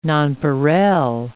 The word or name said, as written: nonpareil